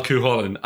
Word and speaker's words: Cúchulainn: Josh can spell Cúchulainn.